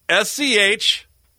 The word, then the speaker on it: Schitt's: Now I gotta go out and the show that won the Emmys for comedy, Schitt's Creek. I've got to go win that, and they're the winner. SCHITT'S. That's how you pronounce it.